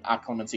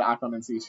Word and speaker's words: occlumency: And there is an accent on the loo, so Dan would technically be correct, but I'm going to still say occlumency because I like it that way.